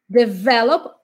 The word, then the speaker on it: develop: Develop, a sílaba mais forte é o V.